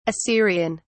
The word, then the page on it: assyrian: British English